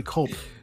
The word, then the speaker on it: Culp: Buck Buchanan, Bobby Bell, Jerry Mays, Curly Clump. Clump? Okay, Clump. – No, Curly Culp. – Culp? – C-U-L-P, Culp. – It's fucking spelled wrong here. Hold on. – C-U-L-P, Culp.